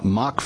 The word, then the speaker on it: Mach: Mock Funding is spelled M-A-C-H, like the speed, like mock speed, not mock like M-O-C-K.